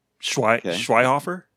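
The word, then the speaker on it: Schweighöfer: Schweizhofer? – That sounds good. Schweighofer? – Yeah, I tried my best, you know.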